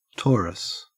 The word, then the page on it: torus: Australian English